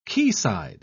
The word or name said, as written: quayside